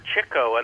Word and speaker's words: Chico: However, Chico, and that's the correct pronunciation of his, pronunciation of his name because he huh.